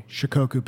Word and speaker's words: Shikoku: Well, and you talk about that in your book on the, can you pronounce that for me? – Shikoku pilgrimage. – Shikoku pilgrimage.